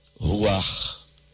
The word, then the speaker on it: ruach: No hebraico, a língua do Antigo Testamento, a palavra espírito é ruach. Entendeu? Ruach. Se escreve R-U-A-C-H. O C-H final soa como R, aspirar.